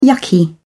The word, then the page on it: yucky: British English